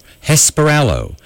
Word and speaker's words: Hesperaloe: It's a plant called Hesperalo, H-E-S-P-E-R-A-L-O-E, pronounced Hesperalo.